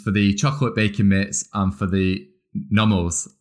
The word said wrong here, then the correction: Norbles: Am I pronouncing that right? – The Norbles. The Norbles.